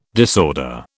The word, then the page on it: disorder: British English